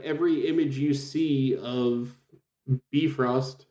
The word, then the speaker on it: Bifrost: It's the way it's read by Will Wheaton, Bifrost. It's actually pronounced Bifrost or Bifrost. It almost sounds like Bifrost.